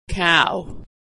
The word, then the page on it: cow: aw